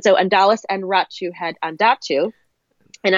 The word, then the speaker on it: Andatu: His name is Undatu, A-N-D-A-T-U.